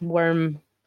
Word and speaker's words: wyrm: It's worm, as in worm with a Y, so W... Y-R-M, yeah.